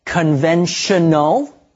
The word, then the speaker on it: conventional: Okay, you know, for example, the morpheme T-I-O-N is pronounced shun. So, if you see it in a high-level word you don't recognize, like perhaps the word conventional, that's how you should pronounce it.